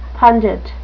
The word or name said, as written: pundit